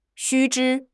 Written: 须知

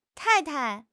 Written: tai4 tai5

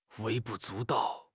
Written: 微不足道

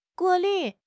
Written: 过滤

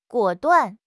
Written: 果断

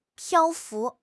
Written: 漂浮

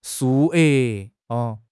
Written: 数额

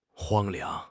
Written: 荒凉

